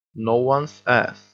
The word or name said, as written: no one's ass